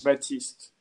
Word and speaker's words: Baptiste: Baptiste? – Yeah, except the only thing is you technically don't pronounce the P, so it's like Baptiste, if you say it.